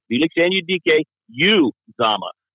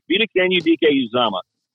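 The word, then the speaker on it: Felix Anudike-Uzomah: Felix Anudike Uzomah. Say it that way, and you'll be in the top 5% because very few people are pronunciating it the right way. Felix Anudike Uzomah.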